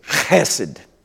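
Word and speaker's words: chesed (חסד): There is a word in Hebrew that you got to kind of clear your throat at the back when you do it. It's spelled in English, typically, H-E-S-E-D. And you have to say it like this, chesed, chesed. Everybody say chesed.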